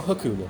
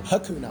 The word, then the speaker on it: Hakuna: Makuna Matata. – Indeed. – Is it Makuna or Hakuna? – Hakuna. – Oh, Hakuna. God, I've been saying it wrong for 30 years.